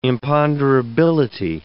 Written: imponderability

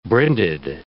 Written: brinded

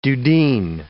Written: dudeen

